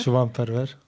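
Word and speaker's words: Şivan Perwer: C'est un chant composé par le poète kurde Şivan Perver. Je ne prononce pas très bien, me corrigeras. – Şivan Perver. – Merci.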